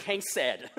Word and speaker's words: chesed: You have to reach into the throat, but it's chesed.